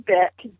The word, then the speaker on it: Byk: Because I always said, I think I always said Bick and or Bike, and we're because it's spelled B-Y-K. How do you say that one? – That one's Bick. Bick. You're correct on that.